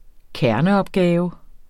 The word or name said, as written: kerneopgave